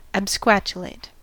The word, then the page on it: absquatulate: American English